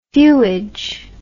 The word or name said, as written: feuage